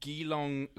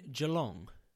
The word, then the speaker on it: Geelong: So, for example, there's one famous court in Australia in a school called Geelong Grammar, which has been described as... Do you mean Geelong? I believe that's how it's pronounced.